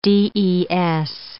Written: DES

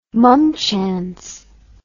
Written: mumchance

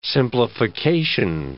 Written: simplification

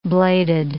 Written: bladed

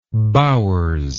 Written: bowers

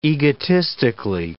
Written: egotistically

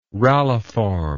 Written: ralliform

